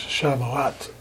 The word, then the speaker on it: Shavuot: The Jewish festival of Shavuot, am I pronouncing that right? – I believe it's Shavuot.